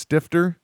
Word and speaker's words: Stifter: So speaking of sexy, though, I came home, Josh, and the last name is pronounced Stifter. – Yep. – Okay, good. As in, did you pay her? No, I stiffed her.